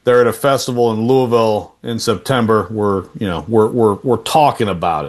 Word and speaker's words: Louisville: I'm just proud of you for pronouncing Louisville correctly. That's good stuff. – Louisville, that's right.